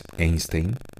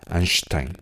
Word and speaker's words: Einstein: Otro libro que me parece bueno para niños pequeños, como hasta de 5 años, es uno que se llama Baby Einstein, o como se castellaniza a veces, Einstein, ¿no? Aunque la pronunciación en alemán es Einstein.